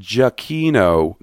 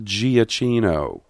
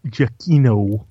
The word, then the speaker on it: Giacchino: It's pronounced Giacchino, not Giacchino. – I do believe it's pronounced Giacchino.